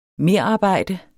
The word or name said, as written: merarbejde